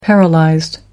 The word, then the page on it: paralysed: American English